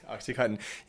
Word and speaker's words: OxyContin: Will I say it right, though? OxyContin? Is that right. – It? – OxyContin. Yeah, one of the opioid family.